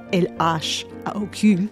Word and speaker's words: L.H.O.O.Q.: Duchamp signed a postcard of the Mona Lisa with a mustache and the letters L-H-O-O-Q. When you pronounce those letters in French, L-H-O-O-Q, it sounds like a phrase meaning she has a hot ass.